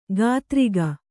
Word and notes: ಕಾತರಿಗ: kātariga

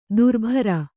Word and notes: ದರ್ಬಾರಿ: Kannada

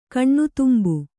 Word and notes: ಕಣ್ಣುತುಂಬು: kaṇṇutumbu

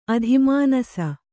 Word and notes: ಅತಿಮಾನಸ: ati mānasa

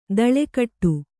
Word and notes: ದಳೆಕಟ್ಟು: Kannada

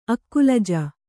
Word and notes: ಅಕ್ಕುಲಜ: akkulaja

